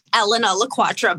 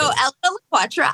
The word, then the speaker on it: Elena LaQuatra: Yes, it's actually pronounced Elena LaQuatra, but I get Elena more often than not, so I accept. Okay. So Elena LaQuatra, I live in Pittsburgh, and today's date is March 24th.